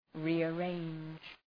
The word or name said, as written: rearrange